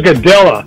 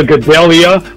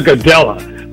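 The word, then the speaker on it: Gadelha: Claudia Gadella. – Yes. – Not this Claudia Gadelia. What the hell is that? It's Claudia Gadella.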